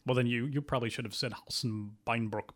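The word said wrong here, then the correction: Hals- und Beinbruch: because I'm sure I'm not pronouncing that correctly. – Aus und Beinbruch. – Well, see, there you go.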